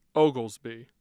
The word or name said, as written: oglesby